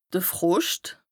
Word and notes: de Froscht: Bas-Rhin; Strasbourg

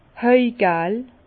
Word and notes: hajgel: Bas-Rhin; Herrlisheim

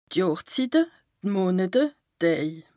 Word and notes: D' Johrszitte, d' Monete, d' Däj: Bas Rhin; Strasbourg

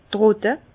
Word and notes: trotte: Bas-Rhin; Herrlisheim

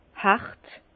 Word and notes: hàrt: Bas-Rhin; Herrlisheim

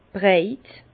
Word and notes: breit: Bas-Rhin; Herrlisheim

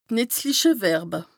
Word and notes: D' nìtzliche Verbe: Bas-Rhin; Strasbourg

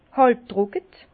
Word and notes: hàlb-trocke: Bas-Rhin; Herrlisheim

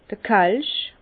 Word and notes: d'r Kelsch: Bas-Rhin; Herrlisheim

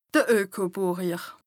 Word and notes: Ökobùrjer: Bas Rhin

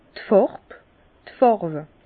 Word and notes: d' Fàrb, d' Fàrwe: Bas-Rhin; Herrlisheim

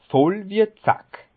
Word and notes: Voll wie e Zack: Bas Rhin; Schiltigheim

